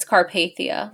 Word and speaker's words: Carpathia: It's Carpathia. Like the Carpathian Mountains where, right. where Count Dracula lives.